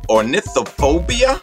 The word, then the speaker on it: ornithophobia: He is a phobia expert who has treated people with ornithophobia. Chris, am I saying that right? – That's correct. Yep.